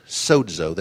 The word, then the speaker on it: sozo: The Greek word for salvation comes from the word sozo, that's spelled S-O-Z-O, and it means to save, heal, preserve, and made whole, be made whole.